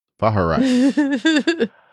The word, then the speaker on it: Vaharai: I almost gave it another syllable there. Vaharai.